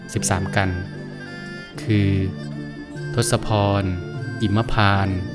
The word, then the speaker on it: ทศพร: สวัสดีครับพบกับรายการคำวัดวันนี้เสนอคำว่าทศพรคำว่าทศพรสะกดด้วยททหารสกสาราพอพานรอเรืออ่านว่าทศพร